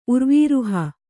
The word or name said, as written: ಉರ್ವೀರುಹ